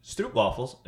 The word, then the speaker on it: Stroopwafels: So Jason the lead, 1-0-0. – Mm-hmm. – Next statement. Stroopwafels. Am I saying that right? – Yeah.